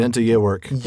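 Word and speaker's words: enta geweorc: What was the old English phrase, Enta Georg? – Enta Georg, Enta Yewerk. – That's right, Enta Yewerk. – Yeah, I think the G is pronounced as a Y.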